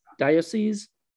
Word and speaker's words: diocese: Diocese.